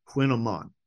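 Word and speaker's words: kouign-amann: So this thing is a quinoa mon, and it sounds like you're saying queen, like Q-U-E-E-N, but it is spelled K-O-U-I-G-N. It's pronounced queen or quinamon, and then the other word, A-M-A-N-N, quinamon. I don't know. It's a word from Breton.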